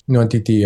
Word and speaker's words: Nwantiti: Is that how you say it correctly? – Yes. Nwantiti, yeah.